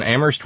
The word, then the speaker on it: Amherst: Thank you to Terry in Amherst, where only the H is silent.